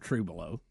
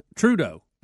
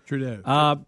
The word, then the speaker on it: Trudeau: How do you say his name? Right. It's not Trudeau. Trudeau. That's it. Justin Trudeau, right? – Yeah, Trudeau.